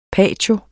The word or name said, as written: patio